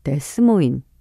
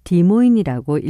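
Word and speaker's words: Des Moines: 간혹 미국 사람들 중에서 영어 철자 그대로 읽어서 데스모인 또는 디스모인이라고 하는 사람들도 종종 있다고 하네요. 하지만 이 디모인은 수도사라는 뜻을 가진 프랑스 말을 어원으로 하고 있어서 디모인이라고 읽어야 한다고 합니다.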